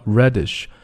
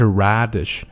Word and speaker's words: reddish: 注意开口都非常小 reddish, 不是 radish, 也不是 reddish, 而是 reddish hue.